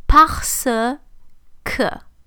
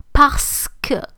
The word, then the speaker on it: parce que: So parce que, or parce que, or sometimes because of like informal oral style, we don't even hear the R in the middle. It can be parce que.